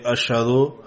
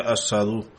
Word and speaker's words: Ashadu: Por ejemplo, algunos nativos de África pronuncian la letra árabe Shin como Sin. Así, durante el Azan, en lugar de Ashadu, Hazrat decía Asadu.